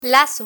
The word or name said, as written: lazo